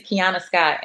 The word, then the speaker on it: Keonna Scott: Everything is Keonna Scott, and my name is spelled very differently. My name is spelled KEONNASCOTT and comedian.